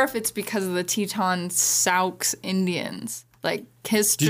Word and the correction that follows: Sioux: Did you say Sauk? – I don't know how to say it. It's S-I-O-U-X. – It's the Sioux. The Sioux Indian.